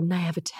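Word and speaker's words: naïveté: You're supposed to say it like Moira Rose, so naivete.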